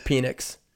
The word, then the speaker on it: Penix: And first of all, how do you say his last name? Is it just straight up Penix, or is it Penix? – I'm going with Penix. – Yes, good.